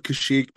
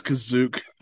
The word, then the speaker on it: Kashyyyk: It's called Kashyyyk, but they didn't know how to say that. So it's Kazook to them.